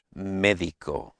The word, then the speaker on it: médico: Médico. – Médico, and it has that I sound.